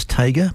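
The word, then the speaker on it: Tjader: Cal Tajer, T-J-A-D-E-R. I believe it's pronounced Jager, or some people tell me it's Tager, but there you are, you have a choice.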